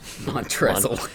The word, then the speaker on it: Montrezl: Montrezl. – The L is silent.